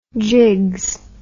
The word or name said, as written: jigs